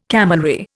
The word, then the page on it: camelry: Indian